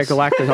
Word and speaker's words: Galactus: I brought this up to my friend about how they can incorporate, I'm going to butcher the name, Galacticus. – Galactus? – Yeah, Galactus. I'm sorry.